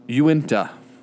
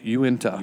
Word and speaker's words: Uinta: He named us after a mountain range that even the people here mispronounce. Uinta is what it is. Uinta. – Uinta.